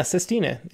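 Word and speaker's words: sestina: I'm not sure if I'm going to pronounce the form right, but it's Sistina? – Yeah, Sistina, exactly.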